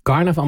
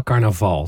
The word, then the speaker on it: carnaval: Niet carnaval, maar carnaval, toch? – Ja, dat klemtoon op het laatst.